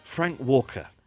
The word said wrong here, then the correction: Frank Welker: It says Welker here. – It's spelled Welker. Frank Welker. I'm wrong. It's Welker.